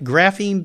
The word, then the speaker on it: graphene: His companies have included Endless Energy, a sulfur grapheme. Am I saying that right? Graphene battery technology? – You are graphene. – Graphene, okay. Battery technology company.